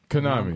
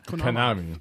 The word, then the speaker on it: Konami: Konami. – I know that's how we say it, but people be saying it different. – Konami.